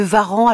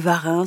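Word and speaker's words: Warens: Alors, on m'a dit qu'il fallait prononcer ainsi. Je vois qu'il y a des rousseauistes qui disent encore Madame de Varan, donc je suis un peu perdue, mais je vais dire comme on m'a dit, Madame de Varan.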